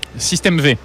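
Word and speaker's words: System V: de ce système 5, je crois, ou V, comment vous prononcez ça ? – Ah, système V.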